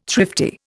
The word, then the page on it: thrifty: Indian